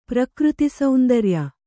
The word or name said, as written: ಪ್ರಕೃತಿಸೌಂದರ್ಯ